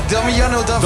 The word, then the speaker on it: Damiano David: Maar voor Diamond, spreek je dat uit? Diamond David? – Damiano David. – Damiano David, oh ja.